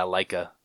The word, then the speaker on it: Leica: Leica? – Yeah, Leica.